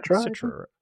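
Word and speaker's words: Cetra: And they believe that Aerith, because she's the last of the Setra, or maybe I'm saying that wrong. Is it Setra? – Setra. – Setra, yeah.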